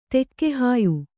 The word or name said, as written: ತೆಕ್ಕೆಹಾಯ್